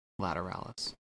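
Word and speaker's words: Lateralus: It turns out they pronounce it Lateralis.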